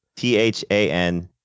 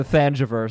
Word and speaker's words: Thanjavur: It's THANJAVUR.